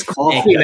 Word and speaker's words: Coffee Maker: Coif Maker. Is that how you say it? Coif Maker? – I think Coif, it's coffee. Maker. I think it's coffee, as in a cup of coffee, isn't it? – Coffee Maker, 4-1.